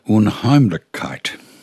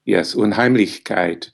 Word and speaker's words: Unheimlichkeit: You quote a wonderful word of Freud's, and I'm going to risk a clumsy attempt at pronunciation, Unheimlichkeit. – Yes, Unheimlichkeit. – See, I got it wrong. I got it wrong. – You were close. You were really close.